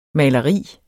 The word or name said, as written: maleri